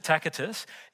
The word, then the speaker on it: Tacitus: So let's look at Tacitus, that's how you pronounce it, Tacitus.